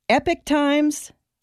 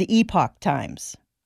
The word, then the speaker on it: Epoch Times: but I've heard it pronounced both ways. Epic Times and Epoch Times.